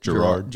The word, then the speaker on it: Gerard: Gerard.